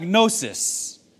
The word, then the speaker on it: gnosis: Normally, in Greek, when you were to say knowledge, you would use the word gnosis with the silent G.